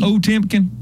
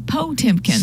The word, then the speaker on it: Potemkin: You spell Potemkin. It P-T. That's not even a syllable. – P-O-T. – It's Potemkin. – Potemkin. – See? It sounds better when you say it that way.